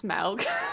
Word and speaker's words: Smaug: It's pronounced Smaug.